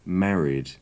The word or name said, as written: married